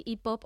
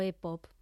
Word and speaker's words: hip-hop: Yo te pregunto, ¿qué es eso del hip-hop? ¿Se dice así? – Sí, hip-hop o e-pop. Aquí a gusto del consumidor. Como estamos en España, se puede decir e-pop, ¿eh?